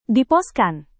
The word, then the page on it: diposkan: indonesian